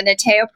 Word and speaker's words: NITEO: But speaking of innovation, talk about, and I hope I pronounce it correctly, it's NITEO, right? The NITEO program? – The NITEO program, which means thrive in Latin.